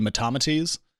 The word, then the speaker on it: Metamates: but they said you should pronounce it like a Greek philosopher, so it's actually Metamates.